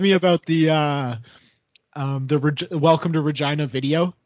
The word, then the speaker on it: Regina: Yeah, I'm in Regina. Mm-hmm. The city that rhymes with fun.